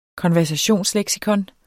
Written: KONVERSATIONSLEKSIKONER

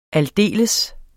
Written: aldeles

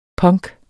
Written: punk

